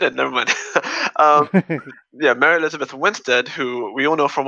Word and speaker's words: Winstead: Is it Wanstead? Oh god, wait, I think I got her name wrong. Wait, cut this bit out. – I don't know. Was it Helena? – Yeah. And Mary Elizabeth Winstead, not Wanstead.